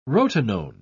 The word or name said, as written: rotenone